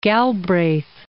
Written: Galbraith

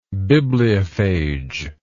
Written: bibliophagic

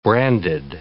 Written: branded

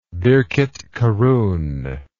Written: birketkarun

